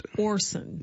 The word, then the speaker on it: Orsund: So it is Orson, as you see it, just the D is silent. – Orson.